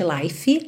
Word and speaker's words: life: Atenção para não pronunciar o E, lembra que não existe life, life.